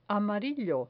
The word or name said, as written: amarillo